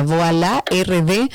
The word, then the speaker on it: Voilà: Voilà RD, así como se pronuncia, Voilà RD en redes sociales y a Gaby también puede conseguirla como gabriela.reginato.